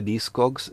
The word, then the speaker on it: Discogs: Che cos'è Discogs? Discogs, che si pronuncia D-I-S-C-O-G-S,